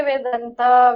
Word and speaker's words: Vedanta: Do you say Vedanta? – We can say Vedanta. We can say Advaita, Dharma. I mean, Vedanta is like based on the Vedas, right?